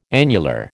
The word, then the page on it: annular: American English